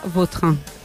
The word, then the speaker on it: Vautrin: La original francesa, Vautrin. – Ahí pega un Vautrin.